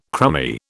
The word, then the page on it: crummy: British English